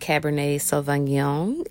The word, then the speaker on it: Cabernet Sauvignon: We are drinking Liberty Creek Cabernet Sauvignon. It is, or did I say that wrong? – No, you're right.